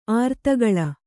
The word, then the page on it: ಅರ್ಧಗೋಲ: ardhagōla